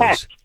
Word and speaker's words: Katz: Cates, is the pronunciation Cates or Katz? I want to get it correctly, Deb, please. – Katz. K-A-T-Z.